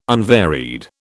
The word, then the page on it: unvaried: British English